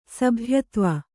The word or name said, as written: ಸಭ್ಯತ್ವ